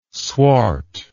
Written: swart

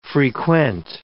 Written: frequent

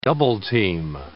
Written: double-team

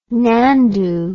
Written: nandu

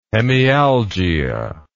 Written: hemialgia

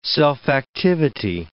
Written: self-activity

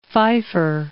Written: Feiffer

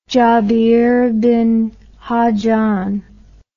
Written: jabir ibn hayyan

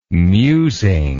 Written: musing

